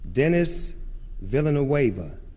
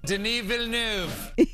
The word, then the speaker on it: Denis Villeneuve: Well, here's, like, for instance, Denis Villeneuve, the director, here's how you're supposed to pronounce it. – Denis Villeneuve. – And then, you know what?